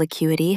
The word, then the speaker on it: acuity: Intellectual acuity helps you solve tough problems fast. Stress the second syllable.